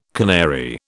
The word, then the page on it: canary: British English